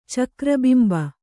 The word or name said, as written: ಚಕ್ರಬಿಂಬ